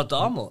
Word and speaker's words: Dahmer: Er Wird Dahmer ausgesprochen. – Ah, Dahmer.